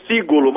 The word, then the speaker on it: Cigolo: Pronúncio é Cigolo, mas não tem acento.